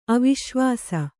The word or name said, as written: ಅವಿಶ್ವಾಸ